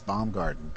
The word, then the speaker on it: Baumgarten: Well, it's Dallin, D-A-L-L-A-N. – Dallin? – And yeah, rhymes with gallon, and it's Baumgarten, B-A-U-M-G-A-R-T-E-N.